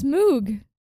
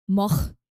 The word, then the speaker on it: Moog: It's not pronounced moog. What is it? Moog. No. – Yes. There's two O's. Yes. – It, that's that's the way that the guy who invented it pronounces it. Technically, it's pronounced moch, but no one can say that in English speaking countries, so it's moog.